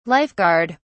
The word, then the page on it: life guard: British English